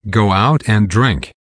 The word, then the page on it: go out and drink: American English